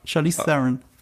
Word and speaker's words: Charlize Theron: Charlize Theron. – Theron, nicht Theron? – Ja, Charlize Theron.